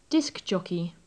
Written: disc jockey

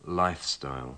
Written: lifestyle

